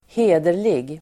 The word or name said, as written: hederlig